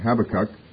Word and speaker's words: Habakkuk: Some of you may have heard Habakkuk pronounced differently. Habakkuk is how some people pronounce it, and I wondered for years which was correct pronunciation, and I don't know that I ever got a final authority on that, but I had an old King James Bible that broke down the names phonetically and put accents and so forth on Hebrew names. Whether they did it correctly or not, I don't know, but Habakkuk was the pronunciation.